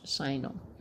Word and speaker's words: assignor: But it was a signer. I don't even know if I have a feeling I'm mispronouncing that. – And a signer. – A-S-S-I-G-N-O-R. – Right. I think it is pronounced a signer.